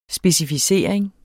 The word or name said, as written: specificering